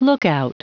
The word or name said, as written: lookout